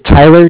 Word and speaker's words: Tyler: My name is Tyler, T-Y-L-E-R, and I'm calling from Bank of Cardiff. It's spelled C-A-R-D-I-F-F, as in Frank Frank.